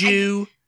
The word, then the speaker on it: due: No, in Britain do, it would be Jew, do, and Jew. – Okay. – There's like Jew, you know, it's like like D-U-E, you yeah, kind of pronounce like Jew.